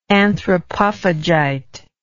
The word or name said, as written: anthropophagite